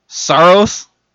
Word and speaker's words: Saros: If you need a goalie to pick up, Saros.